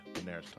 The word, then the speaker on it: Daenerys: Yeah, Daenerys Targaryen.